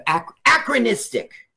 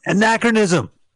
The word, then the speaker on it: anachronism: Yeah, that's another thing that's kind of acronistic. – Acronistic. Apple-nistic? – Okay. – Anachronism.